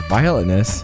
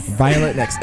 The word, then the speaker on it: Violetness: Her name is Violetness. Am I saying your name right? – Yeah. – You have to say it into the mic. – Violetness. – Violetness.